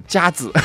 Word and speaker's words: 佳子: 第三份奖品我们要送出的是 8楼昆仑宾馆华清园美容院为你送上的游泳 3小时的门票，以及价值 200元的儿童游泳课一节，恭喜到这个朋友吧，叫做佳子，恭喜。我这么读这么发音对不对？他确实，他是上好家的家，儿子的子，佳子。